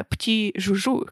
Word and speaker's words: Petits Joujoux: Маркет прислал нам массажную свечу э, пти-жужу, как сказала мне моя подружка Даша Груздева, это читается именно так.